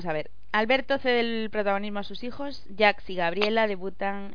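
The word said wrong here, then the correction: Jacques: Jax no, Jack. Pronunciemos bien. Y no un francés de Vallecas. – Pues no, le llámalo Jack.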